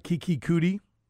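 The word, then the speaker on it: Keke Coutee: Kiki Kuti, which, by the way, that's the pronunciation that was told to me by Patrick Mahomes, his college teammate. So if you are screaming at the radio that I have it wrong, then take it up with Patrick Mahomes.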